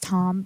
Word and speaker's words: Thom: I know his name's actually pronounced Tom, but I call him Thom.